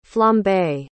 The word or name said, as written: flambé